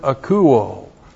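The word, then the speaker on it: Akouo (ἀκούω): Now we continue our exegesis of verse 6 with the present active indicative of the verb akouo. A-K-O-U long O. Akouo.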